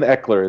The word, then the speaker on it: Ekeler: Ask him, Robert, if he has other stories about covering long shots like the one he shared about Ekeler right before the beginning of the season. Did I butcher that name? – Austin Ekeler is his name.